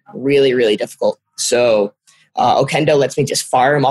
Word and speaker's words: Okendo: So then we actually just downloaded this really nice review app called Okendo. – What does it spell it? Spell it for people. – O-K-E-N-D-O.